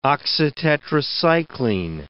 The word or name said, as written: oxytetracycline